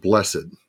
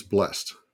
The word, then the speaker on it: blessed: Now, just a little grammatical note we pronounce it blessed mm-hmm. – when it's used as an adjective, an adverb, or a noun. When that word is used as a verb, then it's blessed.